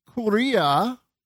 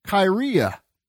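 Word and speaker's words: kyria (κυρία): or the word lady, kuria, in Greek, in modern pronunciation, it would be the word kyria.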